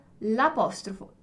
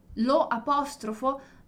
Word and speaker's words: l'apostrofo: L'apostrofo, Lo apostrofo? No, non vado a dirlo, non posso dire così. Devo unire i suoni, quindi l'apostrofo, l'elisione.